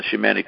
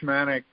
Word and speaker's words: shaman: So we talked about the shamanic tradition. By the way, you were always using the American pronunciation, shaman. – Yes. – And I was using the English pronunciation, shaman, but they're the same thing.